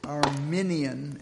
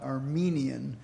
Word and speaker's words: Arminian: It's Arminian, after Jacobus Arminius, not Armenian, which is a race of people from the Caucasian of Eastern Europe.